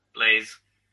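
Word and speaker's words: BlazBlue: How the hell do you pronounce that? – Blaze. – Yeah. Thank you. I heard somebody call it Blaz, and then I was like, wait, have I been saying it wrong all this time? – No, that guy has.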